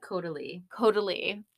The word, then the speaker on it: Caudalie: Caudalie.